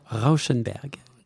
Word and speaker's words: Rauschenberg: qui s'appelle Robert Rauschenberg. – Tu le prononces mieux que moi.